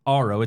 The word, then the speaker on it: Aro: He's the co-founder of Aro.